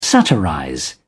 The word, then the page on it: satirize: British English